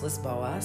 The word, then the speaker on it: Lisboa: We're going to be in Lisbon, as it's spelled, but I heard it's pronounced Lisboa, so that's where we're going to be staying.